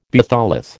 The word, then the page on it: batholith: American English